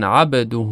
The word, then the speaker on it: عبده: با در کلمه عبد وقتی که تلفظ میشه کمی متمایل به کسره تلفظ میشه: و اشهد ان محمدن عبده.